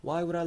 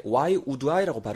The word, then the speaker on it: why would I: 잘 챙겨가시고 발음적인 부분 할 때 why would I라고 발음하시기보다는 그냥 why would I, why would I 따라해보세요. Why would I?